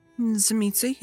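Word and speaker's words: Tzimisce: You're a Zemitzi. Am I pronouncing that right? – Zemitzi, yes.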